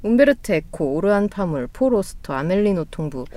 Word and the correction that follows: 파묵: 오르한 파묵 아니에요? 네. – 파몽이라 그랬죠. 파몽. 파몽.